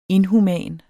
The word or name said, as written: inhuman